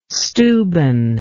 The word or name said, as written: Steuben